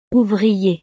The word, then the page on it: ouvrier: frances